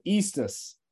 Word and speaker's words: Estes: It's ESTES. – What spelling of Sean? – WN. – Okay. – Yeah, Eastus. There we go.